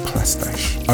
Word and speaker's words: Plastiche: But this is the Plastiche remix of Full Circle. – It's a house thing. – Lezizmo and Full Circle with Plastiche on the remix. I always call him Plastiche, it's actually Plastiche.